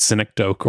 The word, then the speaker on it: synecdoche: This is the same thing happened to me with synecdoche or synecdoche, and I was like, I've seen this word written down.